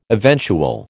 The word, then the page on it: eventual: American English